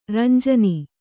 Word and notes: ರಂಜಾನ್: ramjān

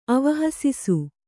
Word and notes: ಅವಹಸಿಸು: Kannada

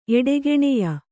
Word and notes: ಎಡೆಗೆಣೆಯ: Kannada

